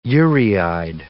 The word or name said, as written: ureide